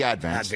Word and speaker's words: Advance: Thank you for pronouncing it like the locals do, putting the syllable, the accent on the first syllable. It's not the Advance, it's the Advance.